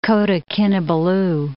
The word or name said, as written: Kota Kinabalu